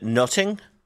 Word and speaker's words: nothing: It's a triple pun, because in the dialect of the time, the word nothing would have been pronounced as closer to nutting,